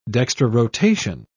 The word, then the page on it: dextrorotation: British English